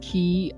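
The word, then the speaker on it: quay: So it's pronounced key and not squee.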